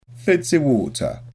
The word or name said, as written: fizzy water